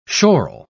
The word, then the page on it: schorl: British English